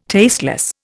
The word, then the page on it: tasteless: Indian